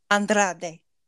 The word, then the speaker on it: Andrade: Andrade.